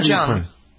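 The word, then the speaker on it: Jeong: Is it Jung? – Ken Jung. – Jung, okay. Mm-hmm. Did you know he was a doctor? – Yeah, everyone knows that. That's why they call him Dr. Ken. – Jung knows him.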